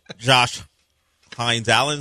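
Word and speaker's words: Josh Hines-Allen: Yeah, forget Josh Hines Allen. Is that how you say it? – Yeah.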